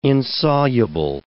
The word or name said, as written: insoluble